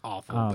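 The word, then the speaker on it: offal: It's pronounced awful, but it's spelled differently.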